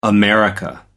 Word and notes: america: American English

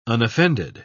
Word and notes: unoffended: British English

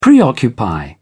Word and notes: preoccupy: British English

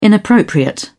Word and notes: inappropriate: British English

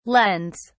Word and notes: lends: British English

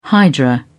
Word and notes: hydra: British English

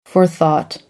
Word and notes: forethought: American English

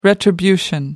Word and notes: retribution: American English

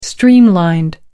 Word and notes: streamlined: British English

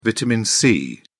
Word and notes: vitamin c: British English

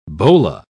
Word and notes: bola: British English

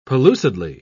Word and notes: pellucidly: British English

